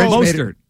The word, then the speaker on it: Mostert: But no, if Jimmy Garoppolo's here, you're telling me that Raheem, how do you say his last name? – Mostert.